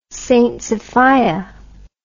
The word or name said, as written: st sophia